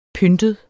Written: pyntet

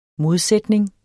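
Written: modsætning

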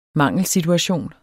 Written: mangelsituation

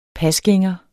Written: pasgænger

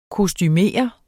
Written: kostumere

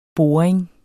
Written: boring

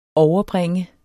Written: overbringe

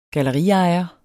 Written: galleriejer

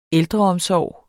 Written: ældreomsorg

